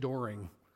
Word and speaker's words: Doring: I'm just Rich Doring. Rhymes with boring.